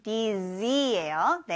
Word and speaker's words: dizzy: 여기에 z 발음 dizzy예요.